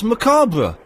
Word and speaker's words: macabre: You overemphasise the R. – No, I don't. I pronounce the word as it should be pronounced, macabre.